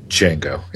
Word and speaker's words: Django: Django. – And the strap line on the movie posters here is the D is silent.